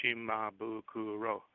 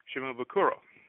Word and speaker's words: Shimabukuro: So, but the name is Japanese, which means no stress on any of the syllables. So basically, what you just say is Shimabukuro, Shimabukuro. Same thing with Israel Kamaka Viva Oli.